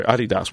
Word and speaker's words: Adidas: Excuse me, Adidas. – Oh, sorry, Adidas. Well, this is, I'm using the English-Americanized pronunciation. – But the real name of the company is Adidas.